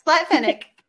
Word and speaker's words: Splatfennec: Yeah, Splatfinic.